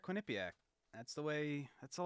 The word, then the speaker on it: Quinnipiac: Wait, you just said it wrong. You just said Quinnipiac. – No. – Quinnipiac. – Yes, thank you.